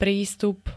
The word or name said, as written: prístup